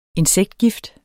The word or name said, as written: INSEKTGIFT